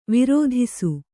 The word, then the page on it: ವಿರಾಧಿಸು: virāḍhisu